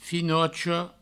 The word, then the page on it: finocha: finocho; Provençal